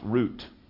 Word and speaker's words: Ruth: It's pronounced root, like we would say R-O-O-T. Yep. There's no T-H sound in the Hebrew language. So it's a guttural T.